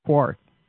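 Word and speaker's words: Qarth: Ken, any thoughts on Quarth? Did I say that right? – Yeah. It's like beating Danny's dead horse.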